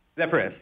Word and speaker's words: Zephyrus: And you guys with the Zephyrus have taken, I'm pronouncing it right, the Zephyrus, right? – Zephyrus, yeah. – Perfect. – That's correct.